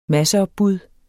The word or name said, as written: masseopbud